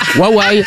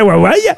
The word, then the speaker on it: Huawei: Vous qui êtes asiatique, Huawei. – Comment on prononce? – Huawei. – H-U-A-W-E-I. – Huawei. – Ah ben voyez. – Huawei. – Qui c'est qui avait raison? C'est bien Huawei.